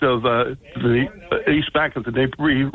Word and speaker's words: Dnieper: Dnieper River. The D is not pronounced. It's Dnieper or Dnipro River, okay?